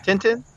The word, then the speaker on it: Tintin: Tintin? – It's spelled T-I-N-T-I-N, but I think it's pronounced Tintin because of language.